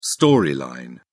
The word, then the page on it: storyline: British English